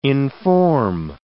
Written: inform

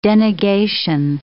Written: denegation

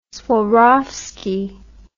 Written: swarovski

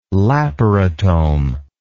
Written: laparotome

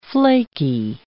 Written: flaky

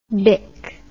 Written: bic

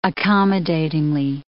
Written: accommodatingly

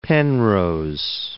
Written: Penrose

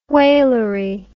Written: whalery

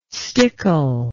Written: stickle

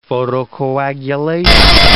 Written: photocoagulation

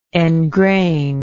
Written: engrainedly

